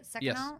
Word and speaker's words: Seconal: Like a Seconal. Seconal. Is that how you say it? Seconal? – Yes.